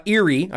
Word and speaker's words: Eyre: So Lieutenant General Erie, I believe is how you pronounce his name, E-Y-R-E, said he doesn't understand why North Korea is, quote, pushing so hard for the end of war declaration.